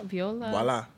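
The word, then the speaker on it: voilà: He brought Viola? – Voila.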